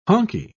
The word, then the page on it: honkey: British English